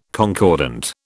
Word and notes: concordant: British English

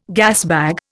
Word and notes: gasbag: Indian

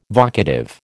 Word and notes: vocative: American English

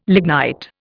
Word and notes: lignite: Indian

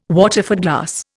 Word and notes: Waterford glass: Indian

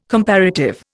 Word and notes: comparative: Indian

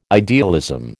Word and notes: idealism: American English

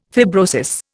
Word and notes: fibrosis: Indian

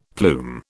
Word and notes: plume: British English

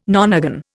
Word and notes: nonagon: Indian

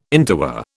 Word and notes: interwar: British English

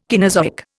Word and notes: Caenozoic: Indian

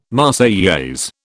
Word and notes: Marseillaise: British English

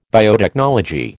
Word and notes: biotechnology: American English

